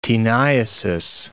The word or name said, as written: taeniasis